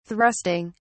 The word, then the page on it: thrusting: British English